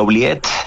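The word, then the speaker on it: oubliette: Hay un lugar que se llamaba La Ubiette. – Sí. – No sé cómo se pronuncia, si se pronuncia así. – Sí, La Ubiette. Es un poco del término francés, como de oublier, como de olvidar, ¿no?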